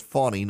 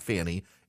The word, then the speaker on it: Fani: I've said Fannie Willis, and someone told me the other day it's Fannie, not Fannie. F-A-N-I is the pronunciation. I've been saying Fannie, but it's Fannie apparently.